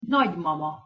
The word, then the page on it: nagymama: Hungarian